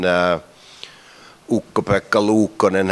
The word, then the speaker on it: Ukko-Pekka Luukkonen: I think the best season Ukko-Pekka Luukkonen has ever had.